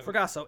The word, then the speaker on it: Fragasso: Directed by Claudio Fragasso? Am I saying that right? – Claudio Fragasso. – Fragasso.